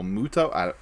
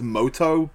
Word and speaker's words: Muto: Or Muto. I think the dub says Moto, but it's spelled M-U-T-O. Japanese, I don't know.